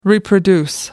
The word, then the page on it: reproduce: American English